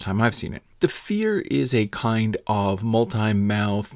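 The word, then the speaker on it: Feyr: That's F-E-Y-R, but it's pronounced Fear, and it's also all about fear.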